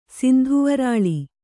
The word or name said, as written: ಸಿಂಧುವರಾಳಿ